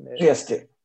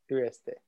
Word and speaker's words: Trieste: Yeah, is it Trieste? Is that where it is? I think Trieste. – Trieste. – I think that's the way to pronounce it.